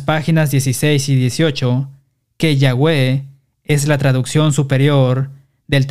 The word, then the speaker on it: Yahweh: Las transcripciones griegas también indican que YHWH debería pronunciarse Yahweh.